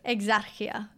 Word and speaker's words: Exarchia: Yeah, so this other alternative side of town is called Exarchia. I think I'm saying that right. – I think you've got it. – Exarchia.